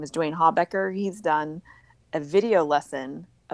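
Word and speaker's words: Habecker: Hawbecker. – Okay, yeah. – H-A-B-E-C-K, yeah.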